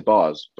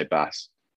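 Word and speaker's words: Bas: Uh, he he says bars, but obviously oh. we just say bass.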